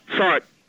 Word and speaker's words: Sartre: So how do you pronounce the philosopher? Sartre or Sartre? – Sartre. Jean-Paul Sartre. Yeah. – Yeah, but I would say Sartre.